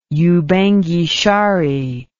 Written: Ubangi-Shari

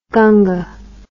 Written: gunga

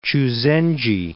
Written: Chuzenji

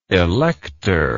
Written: electorship

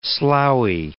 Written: sloughy

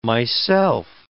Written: myself

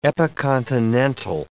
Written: epicontinental

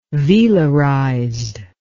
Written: velarized

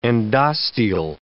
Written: endosteal

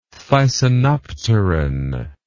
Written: thysanopteran

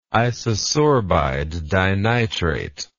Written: isosorbide dinitrate

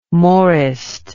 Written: maurist

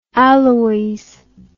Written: Aloys

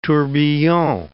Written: tourbillon

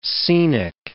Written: scenic

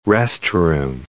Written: restroom

